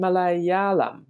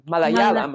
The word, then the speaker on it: Malayalam: had six or seven books translated from Arabic into uh, Malayam. Ma, no, ma, how do you say it? Malayalam? – Malayalam, Mal- I think it is. Malayalam, yes.